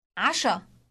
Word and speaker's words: عشاء: عشاء عشاء Note the initial sound pronounced in the throat.